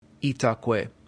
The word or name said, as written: itaque